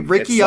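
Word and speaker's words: Rikki: Ricky is spelled R-I-K-K-I. I guess it'd be Ricky Underwood, like, George Underwood. – It's like Ricky Lake.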